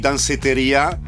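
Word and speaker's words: Danceteria: Ma non è solo dance, perché lei ha omaggiato uno dei locali più celebri anche degli anni 80 a New York, Dansetoria. Ma lo pronuncio bene? Me lo sono sempre chiesto. No, male. Qual è la pronuncia? O dici danseteria. – Danseteria. Ma... – oppure Dansetoria.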